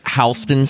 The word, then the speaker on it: Houston: And you know, my friend, Tommy Hudson, Houston. I can't pronounce names. – It's like Houston Street in New York, but it's spelled Houston.